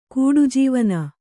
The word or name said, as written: ಕೂಡುಜೀವನ